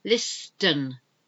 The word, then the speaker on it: listen: A depot is where you are stored, like a warehouse, depot, D-E-P-O-T. Listen to me, listen while I tell you these things, listen, listen. You don't pronounce the T, L-I-S-T-E-N, listen. Whistle. I don't think I can whistle at the moment. Whistle. W-H-I-S-T-L-E.